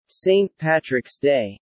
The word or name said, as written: St. Patrick's Day